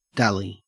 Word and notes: dally: Australian English

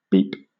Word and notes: beep: British English

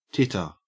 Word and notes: titter: Australian English

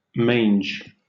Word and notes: mange: British English

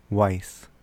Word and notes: wis: American English

